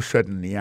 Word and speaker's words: certainly: Alors, en parlant de accent, gardez bien pour un mot certainly, accent tombé sur le premier syllabe là.